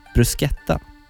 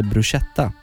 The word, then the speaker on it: bruschetta: Beställer in bruschetta Trodde det hette bruschetta. Ack så fel jag hade. C och H uttalas K på italienska.